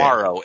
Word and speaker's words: Mauro: Is it Morrow or Mario? How do you say Morrow. – It? – It's Morrow. It's okay. M-A-U-R-O.